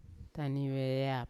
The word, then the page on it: taune veyap: Yawa